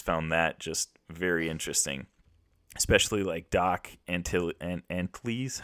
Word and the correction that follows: Antle: Doc Antle.